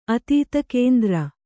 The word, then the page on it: ಅತೀತಕೇಂದ್ರ: atītakēndra